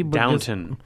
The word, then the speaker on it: Downton: Isn't it Downtown Abbey? – Downton. – Downton. – It's just pronounced different. – No, it's Downton.